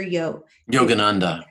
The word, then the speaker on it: Yogananda: Yogananda.